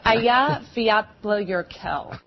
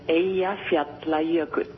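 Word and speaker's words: Eyjafjallajökull: It's Aya Fiatla Yerkel. – Huge clouds of ash shot into the air by Iceland's, I hope I'm saying this right, volcano. – The pronunciation is Aya Fiatla Yerkel.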